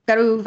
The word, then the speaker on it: Garw: It's in Welsh, G-A-R-W. How would you pronounce that in Welsh? Do you know? – Garu Valley Walk Through the Seasons.